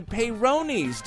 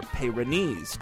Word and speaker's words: Peyronie's: Peyronie's disease. Or Peyronie's disease, depending on how you pronounce it.